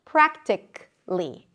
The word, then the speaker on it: practically: For example, practically. It ends with a k sound and then li.